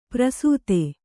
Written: ಪ್ರಸೂತೆ

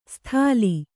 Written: ಸ್ಥಾಲಿ

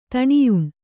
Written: ದಣಿಯುಣ್

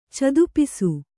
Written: ಚದುಪಿಸು

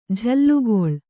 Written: ಜೋಲುಗಾಲು

